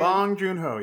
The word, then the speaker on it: Bong Joon-ho: Did I say his name right? – Bong Joon-ho, yes.